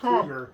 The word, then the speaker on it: Kroeger: But he actually said, people have been saying my name wrong for 20 some years. It's Kroeger. It's pronounced Kroeger. Huh. And he said, the media's been calling me Kroeger for so long, I just didn't feel like correcting him. Yeah. – But that's actually how you pronounce my last name.